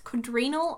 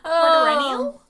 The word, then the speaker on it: quadrennial: Quadrennial? – Quadrennial? Quadrennial? – I think it's quadrennial. Like bicentennial.